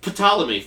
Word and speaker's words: Ptolemy: Macedonian general, Ptolemy? There's a silent P. Who the fuck did... Ptolemy, fuck you, it's there now.